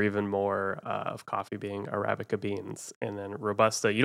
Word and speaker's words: Arabica: And I'm going to butcher this as like Arabic. Arabica? I don't know. – Yeah. Go ahead, Mark. But Stew. – Arabica. – I'm giving Stu the point, but yeah, it's Arabica.